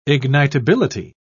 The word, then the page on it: ignitability: British English